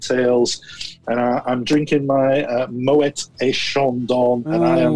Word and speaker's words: Moët: And a beautiful pronunciation of Moët as well, which is the correct pronunciation.